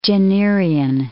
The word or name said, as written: Jennerian